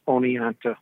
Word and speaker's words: Oneonta: It's pronounced Oneonta.